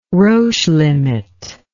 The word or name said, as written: Roche limit